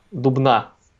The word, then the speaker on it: Дубна: неправильное ударение ставишь. А, Дубна. Вот как правильно а не Дубна.